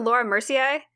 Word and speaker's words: Laura Mercier: Is it Laura Mercier? – Yes.